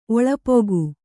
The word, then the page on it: ಒಳಪುಗು: oḷapugu